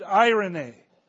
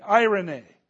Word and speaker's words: eirene: which is the word irene. It's spelt E-I-R-E-N-E, irene.